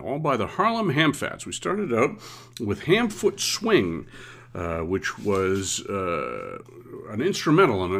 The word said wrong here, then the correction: Ham Fat Swing: I actually mispronounced it. It is Ham Fat Swing.